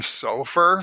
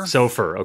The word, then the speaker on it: SOFR: I got to ask you, is there an appropriate pronunciation of the acronym SOFR? – I've gone SOFR. – SOFR, okay. I've heard SOFI, even though, that's more of a naming convention than actually true to the acronym.